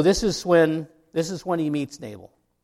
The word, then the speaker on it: Nabal: And there's argument even over how you pronounce Nabal's name. Nabal. We're going with Nabal today.